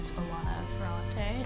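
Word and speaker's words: Elena Ferrante: Sheila Hetty and Alana Ferrante. Mm-hmm. That's how you pronounce her name.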